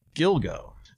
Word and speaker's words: Gilgo: Giglo? Is that how you say it? And Gilgo.